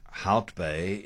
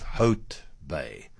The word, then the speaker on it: Hout Bay: And it's the same reason, American Joe, that we say Hout Bay instead of Hote Bay.